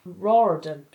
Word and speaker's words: Riordan: Riordan.